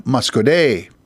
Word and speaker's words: Muscoda: This time, Muscoday is spelled as it is, M-U-S-C-O-D-A, but there is a Y at the end, Muscoday.